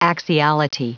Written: axiality

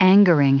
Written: angering

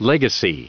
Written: legacy

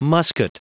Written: musket

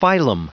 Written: phylum

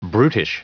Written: brutish

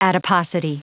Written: adiposity

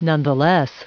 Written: nonetheless

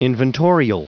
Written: inventorial

